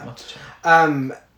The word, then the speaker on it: Matichak: According to Andy Matichak, it was initially... by the way, I'm probably pronouncing her name. Matichak? Yeah. – Matichak.